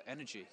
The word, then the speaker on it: Energy: Well, I loved Caroline Gratze from NRJ in Berlin, or rather Energy, because they're not pronounced NRJ in Berlin.